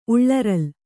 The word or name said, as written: ಉಳ್ಳರಲ್